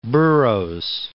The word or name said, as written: Burroughs